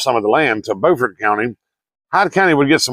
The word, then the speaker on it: Beaufort: We have a Beaufort that's spelled B-E-A-U-F-O-R-T. South Carolina has a Beaufort, which is spelled the exact same way. But South Carolina's is Beaufort, North Carolina's is Beaufort.